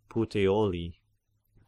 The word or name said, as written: Puteoli